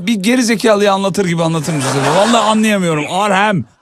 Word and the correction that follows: Arnhem: Bir de ne var? Arnhem. Birazcık telaffuzu zor.